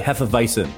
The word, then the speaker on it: Hefeweizen: I'm rocking a Hefeweizen right now. Did I say that right? Hefeweizen. Yeah. I think you get the V in there.